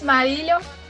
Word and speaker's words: Marillion: Os Marillion.